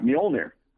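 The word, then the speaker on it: Mjölnir: Mjolnir, of course.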